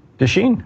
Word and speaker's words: du Chien: Am I saying that right? – Duchesne.